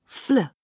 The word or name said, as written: fl